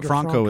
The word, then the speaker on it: Franco: Franco is actually the way it's pronounced, the way I'm told.